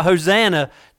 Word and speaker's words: Hosanna: Even those people that were standing there on one day singing Hosea. I said that wrong. But they were shouting out Hosanna to him.